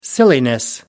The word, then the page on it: silliness: American English